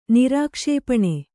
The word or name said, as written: ನಿರಾಕ್ಷೇಪಣೆ